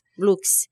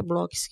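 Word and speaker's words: Blooks: Blux. – E eu fui casada, eu falei com a Elisa Ventura e ela falou que é Blocks que pronuncia.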